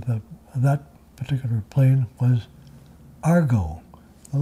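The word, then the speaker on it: Aargau: By actual sheer coincidence, the name of the plane was Argo. Did you hear my like French Swiss pronunciation of that, named after the region of Switzerland.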